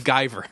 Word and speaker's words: Guyver: I found it's pronounced Guyver.